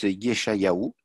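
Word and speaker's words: Yeshayahu (ישעיהו): Alors en hébreu, ça se prononce Yeshayahu.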